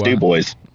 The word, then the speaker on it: Dubois: I was wondering that too, and it's Dubois. – Okay. – Yeah. – That's how it should be. – Our family up north. Our family up north always says Dubois County, and like we're the least French, like fucking.